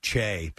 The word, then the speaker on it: Che: I almost said Chi. Che because of the dude from SNL is how you pronounce his last name.